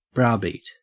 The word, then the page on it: browbeat: Australian English